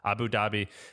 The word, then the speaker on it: Abu Dhabi: Abu Dhabi? – Yeah. – Abu Dhabi.